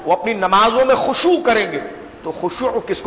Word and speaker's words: خشوع: حلوے والی ہا کے بعد خا، الف با تا فا جیم ہا خا، خا شین عین واو، یا اس کا دوسرا تلفظ جو قرآن میں آیا، الف خا شین واو عین، خشوع، خشوع، خا شین واو عین، خشوع.